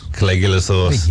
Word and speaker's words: Caligula's Horse: Caligula's Horse. – Caligula's Horse.